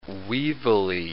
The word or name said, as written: weevilly